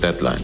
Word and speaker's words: Deadline: Morgen 12 Uhr ist Deadline. Die Betonung liegt auf Dead.